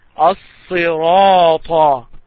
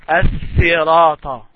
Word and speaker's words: الصراط: الصراط. Make sure that when you say الصراط, you don't pronounce it as as-sirata. It is sad, not seen.